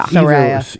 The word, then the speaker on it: Saraya: How is that pronounced? Saraya or Saraya? – Saraya.